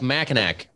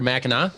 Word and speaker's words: Mackinac: And the UP goes to like Mackinac, right? Or Mackinac? – Mackinac, yes. – Mackinac. – Mackinac. – What is Mackinac then? – Mackinac is the French interpretation, but it's not the correct pronunciation. That's the Americanese version.